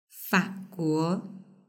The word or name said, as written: faguo